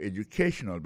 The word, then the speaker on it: educational: We'll be talking about your educational background. Alors, accent tonique là, quand on peut se publier là, il est tombé sur troisième syllabe là.